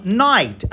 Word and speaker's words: knight: Os cavaleiros da tábua Redonda, que se escreve K-N-I-G-H-T. É como se colocasse a letra K na frente da palavra noite, night. E é bem assim que a gente fala, night. A gente não fala knight.